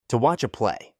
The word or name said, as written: to watch a play